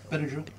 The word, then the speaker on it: Benadryl: Benadryl.